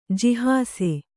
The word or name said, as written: ಜಿಹಾಸೆ